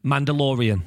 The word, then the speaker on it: Mandalorian: Mandalorian.